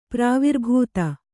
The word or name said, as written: ಪ್ರಾವಿರ್ಭೂತ